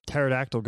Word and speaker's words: pterodactyl: Why can't you hear a pterodactyl go to the bathroom? – I don't know. – Because the pee is silent.